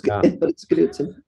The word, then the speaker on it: Knudsen: We do pronounce the K. Not everybody who's a Knutson does. It's a Scandinavian like Smith, you know, it's Knutson. K-N-U-D-S-E-N.